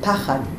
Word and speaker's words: pachad: Liftoach, and pay attention that the gerund here is not a gerund, it's a noun, pachad. And put the accent over the first syllable. Pachad. It's patach patach. Pachad.